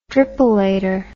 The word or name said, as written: dripolator